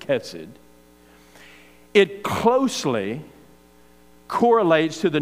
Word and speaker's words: chesed: Or really the way to pronounce it, you almost have to clear your throat to say the Hebrew word is kind of like chesed. That's the way you say it, chesed.